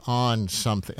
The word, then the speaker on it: On Something: On Something. How do you say it, Anne-Marie? Did you say on something or on something? – On, like, oh, that person must be on something.